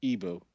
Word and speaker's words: Igbo: Now, when the colonialists came to Nigeria, they couldn't say the word, the sort of a hard GB sound. And so they just said Igbo, I-B-O. So I tend to go with Igbo as a sort of English pronunciation of the word itself.